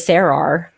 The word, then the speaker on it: Sarar: Because my full name is Sarar. As we know, that's my actual first name. – That's how you pronounce it. – That's how you pronounce it. S-A-R-A-R.